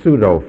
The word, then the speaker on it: pseudo: If you transliterate that, we get the word pseudo from it. But you see, we have a problem because we don't pronounce the P in English as they did in Greek.